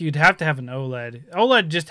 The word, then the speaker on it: OLED: Only thing is I'm concerned about is the fact that do you have to get the Oculid? That's how you pronounce it? – The what? Sorry. – The Oculid, the, you know, the new Switch. – Oh, OLED. Yeah, OLED.